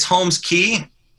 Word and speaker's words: Holmes Cay: How do you pronounce the name exactly? Cause we hear a lot of, is it Holmes Cay, Holmes Cay? – It's Holmes Cay. But part of that name was knowing that people would mispronounce it as Holmes Cay.